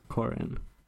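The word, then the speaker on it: Qhorin: Corin. – Mm. – It rhymes with corn.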